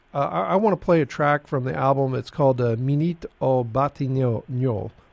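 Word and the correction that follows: Minuit aux Batignolles: I probably butchered that. I apologize if I did. Minute au Batignolle. – Minuit au Batignolle.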